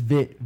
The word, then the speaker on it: Viet: So it's Vit, V I E T, silent E. – Yeah, but just Trap. Yeah, so just pronounce it Vit like.